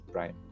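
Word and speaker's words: PRME: And the advocacy of PRIME, which is why we were the first university to sign up to PRIME, that's P-R-M-E, you know, we pronounce it PRIME, Principles for Responsible Management Education, is that the business schools have to be aware that they are part of the problem.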